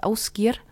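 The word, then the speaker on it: Ásgeir: Oder dessen Musik ich sehr mag, der heißt Ausgir. Das schreibt man wie Aasgeier.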